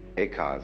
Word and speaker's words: Eka: Eka is Japanese pronunciation, like Zen is the Japanese pronunciation of the Chinese Chan.